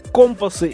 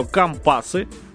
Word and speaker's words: компасы: Итак, трек называется «Компасы», или если вы речные или морские, то «Компасы». Вот, кстати, написание правильно будет в обоих, вернее, произношение будет правильно в обоих случаях, потому что обыватели называют это компасы, а речники и моряки называют это компасы.